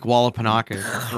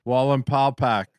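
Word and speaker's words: Wallenpaupack: You're saying it like Lake Wallapanaka or whatever. – Yeah. – Like, Wall and Paw Pack.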